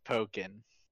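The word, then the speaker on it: Pokkén: I'm pretty sure it's Pokken. – Yeah, like. – To give it that similar pronunciation and to play on Tekken.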